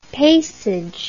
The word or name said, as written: paysage